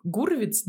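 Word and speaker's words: Hurwitz: Наш У Дэмина Шазела есть этот вот э, Гурвиц, да, у него правильно фамилия читается, это композитор, который Малоленд писал.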